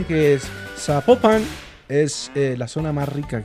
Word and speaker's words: Zapopan: Ah, Zapopan.